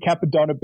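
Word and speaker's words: Cappadonna: He's definitely not. He's butchering it. I'm not. I'm butchering it. – Yeah. – Yeah. The the Mentality Capadonna beat. – You got it. You did it right.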